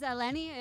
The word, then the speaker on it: Eleni: Hello, my name is Eleni. It rhymes with Penny.